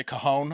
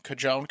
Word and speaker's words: Cajon: Howard Shen, spokesperson for the, I'm going to say Cajon, I'm assuming a silent J, K-A-J-O-N, Cajon, mm-hmm.